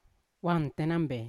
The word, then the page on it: wan tenambe: Yawa